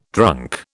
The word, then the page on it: drunk: British English